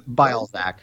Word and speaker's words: Bialczak: It's like a crazy Polish last name. I think it's pronounced Bialczak. I pronounce it Bialczak.